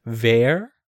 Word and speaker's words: Vor: The official spelling in the English version is V-O-R, which, okay, if we went by English pronunciation, it would be Vor. That's what it would be if it was English pronunciation. That's not to say it's not Vor, but in the Japanese pronunciation, according to the katakana that they use, it's more along the lines of Ver.